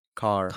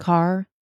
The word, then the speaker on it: Kerr: I believe Deborah Kerr was, is that how you say it? Deborah Kerr? – Carr. – Carr. Carr. Yes. Deborah Carr.